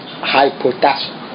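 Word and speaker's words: hypotasso (ὑποτάσσω): Quand on prononce hypotasso.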